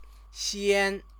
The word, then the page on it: xian: Chinese